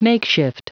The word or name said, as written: makeshift